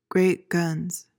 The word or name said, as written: great guns